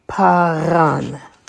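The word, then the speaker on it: paran (פארן): And number 8 is a pay. Notice there's a dot in there. There, pay with a kamatz and an aleph. The aleph is silent. So it will be pa, pa. And then the final syllable is a resh, with a kamatz and a nun sofit. So that would be ran. Ran. So if we put it together, it would be paran. Paran.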